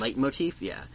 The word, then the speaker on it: leitmotif: But one thing that Leigh did that Frank did not do is, in writing the score, he used something called leitmotifs, or litmotifs. I'm not sure exactly how that's pronounced, but... – I think leitmotif. – Leitmotif, yeah.